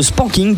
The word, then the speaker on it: spanking: Eh bien, on en parle dans moins de 10 minutes hein, sur VFM, le spanking, du coup. Je le dis bien? – Oui.